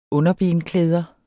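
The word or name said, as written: underbenklæder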